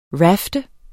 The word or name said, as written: rafte